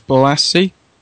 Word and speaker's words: Bolasie: Samuel Margot said it was Bolasie. I believe that's pronounced right. – Yep.